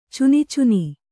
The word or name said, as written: ಚುನಿಚುನಿ